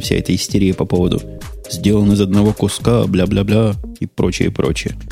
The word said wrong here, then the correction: бла-бла-бла: Так, бля-бля-бля это в русском языке что-то очень матерное. Правильно говорить бла-бла-бла.